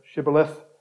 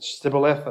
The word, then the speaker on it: shibboleth: Jephthah defeats them, and then we have this strange account of them crossing over the rivers when they couldn't pronounce the word shibboleth. – They're saying sibboleth.